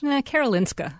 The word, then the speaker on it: Karolinska: Karolinska.